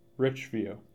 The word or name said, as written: Richview